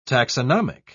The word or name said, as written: taxonomic